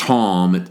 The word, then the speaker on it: tam: But I looked that word up in the Hebrew, and it's a word that it's pronounced Tom. It's transliterated T-A-M, almost like we would say the word tame.